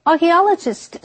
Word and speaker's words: archaeologist: Archaeologist 是指考古学家。注意 CH 后面的 AE 是发长音的 E 念成 archaeologist。